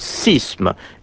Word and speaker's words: séisme: Séisme, prononciation épelée de lettres grecques, a été concurrencé par la variante sisme.